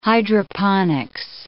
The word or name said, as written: hydroponics